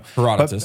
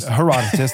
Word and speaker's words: Herodotus: Herodotus. – Herodotus, there we go.